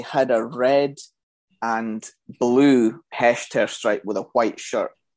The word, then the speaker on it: Hechter: The stripe in the middle, the Hector stripe, did you say? – See, funnily enough, I actually Googled how to pronounce it because I thought if I'm going to come on here and be a smart ass, I better have all my facts and pronunciations right. So it's the Hechter stripe.